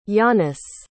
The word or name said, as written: yannis